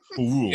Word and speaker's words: Poirot: While on vacation on the Nile, Hercule Poirot, I don't know how to say his name, Poirot? – Poirot. Yeah, I say it like they said it in the movie the whole time.